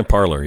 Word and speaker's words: Parler: Parler, however we pronounce it. – Yeah. Parler, yeah.